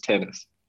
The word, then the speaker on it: TNNS: Yeah, Tennis, I think just Tennis. – Oh, sorry, pronounced Tennis, spelled TNNS, Tennis Live app, download Tennis, all sorts of stuff there.